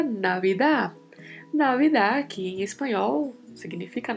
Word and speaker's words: Navidad: Temos aí particularidade de pronúncia. Mais uma vez, V com leve som de B, temos falado bastante disso nos últimos podcasts. E ao final da palavra temos um D que é mudo, Navidad. Navidad.